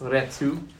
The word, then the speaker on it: retour: And retour, it's actually pronounced retu.